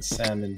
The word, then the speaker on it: salmon: Just shout out to everybody who pronounces the L in salmon.